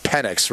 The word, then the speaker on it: Penix: It's pronounced Penix, right? – Yeah.